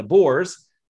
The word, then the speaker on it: Boers: It's spelled B-O-E-R-S, pronounced Boers, like male pigs.